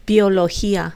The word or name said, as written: biologia